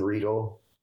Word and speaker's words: Riedel: Jason Riedel. It's R-I-E-D-E-L. It's German spellings backwards on the I and the E, so everybody pronounces it Riedel when they read it.